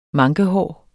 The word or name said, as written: mankehår